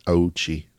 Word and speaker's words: Ouchi: But I watched a video about it, and it was being pronounced as Ouchi.